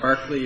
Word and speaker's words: Berkeley: Barkley, even though it's spelled Berkeley? – Yeah, it looks like Berkeley, pronounced Barkley. – That's the British. – Yeah, that is the British association.